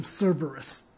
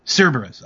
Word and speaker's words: Cerberus: So they have the fight, and then this is where Cerritus, the three-headed dog, comes in. Or I think I said... Did I say that right? – No, I believe it's the name Cerberus. – Cerberus, okay.